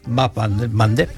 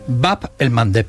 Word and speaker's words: Bab el Mandeb: Bab el Mandeb. – Bab el Mandeb, esa me la voy a apuntar. – Cuesta un poquito pronunciarlo, por eso Mandeb. En Occidente se dice el Mandeb.